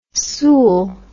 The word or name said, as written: sool